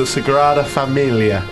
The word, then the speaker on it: Sagrada Familia: I went to a big church called the Sagrada Familia.